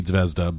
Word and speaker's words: Zvezda: It looks like it should be Zvezda, but the Japanese pronunciation is Zvezda.